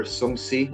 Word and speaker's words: Shang-Chi: Like, other previously unexplored concepts, such as Shang-Chi, or Shang-Chi, as it's properly pronounced, apparently, have been questioned with like the questions like, who's Shang-Chi and why are they making a movie about him?